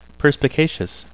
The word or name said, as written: perspicacious